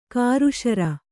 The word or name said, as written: ಕೃಸರಿ